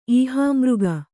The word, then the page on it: ಈಹಾಮೃಗ: Kannada